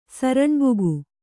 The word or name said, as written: ಸರಣ್ಬುಗು